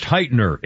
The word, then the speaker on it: Hytner: Steve Hytner, possibly pronounced Heitner, H-Y-T-N-E-R.